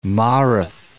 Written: Mareth

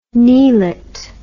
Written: kneelet

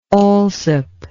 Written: alsop

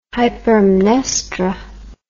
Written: hypermnestra

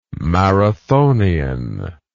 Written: marathonian

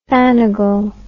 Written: fannigle

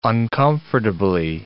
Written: uncomfortably

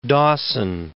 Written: Dawson

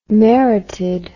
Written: merited

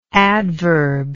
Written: adverbless

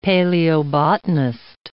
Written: paleobotanist

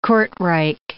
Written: Kortrijk

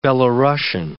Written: Belorussian

